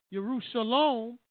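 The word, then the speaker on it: Yerushalom (ירושלים): You've been carried from Jerusalem, which is pronounced Yerushalom, the city of peace, to Babylon, the city of confusion, which is not peace.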